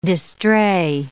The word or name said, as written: distrait